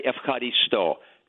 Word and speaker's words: ευχαριστώ: This is how you say ευχαριστώ. It's thank you.